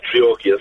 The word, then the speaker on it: Treorchy: In Treorchy. Did I say that right? – Yeah, it's great. Yeah, Treorchy, that's nice.